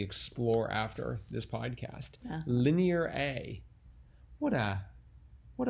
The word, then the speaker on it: Linear A: Linear A. – Oh. I kept trying to pronounce it as lineara, but oh. I see it's linear A.